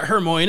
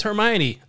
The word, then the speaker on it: Hermione: It's not Hermoine, it's Hermione.